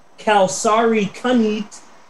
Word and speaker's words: Kalsarikannit: It's pronounced Kalsarikannit, and it is defined in Finnish.